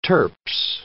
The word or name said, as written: turps